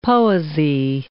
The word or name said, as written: poesy